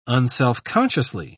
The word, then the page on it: unselfconsciously: British English